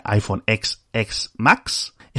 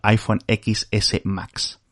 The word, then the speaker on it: iPhone XS Max: Que más que un nombre parece un trabalenguas, lo pronuncias en inglés, que sería iPhone XX Max, o en español iPhone XS Max.